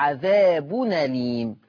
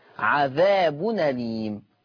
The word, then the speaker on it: عذاب أليم: هشيل الهمزة وهاخد الفتحة أحطها على النون فتبقى عذاب أليم عذاب أليم.